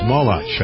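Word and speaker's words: Molotch: Is that how he pronounces his name? No, it's Molotach, I believe.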